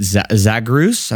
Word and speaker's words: Zagreus: I really like, is it Zagreus? I'm not even sure how to say this word. – Yep.